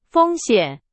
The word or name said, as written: 风险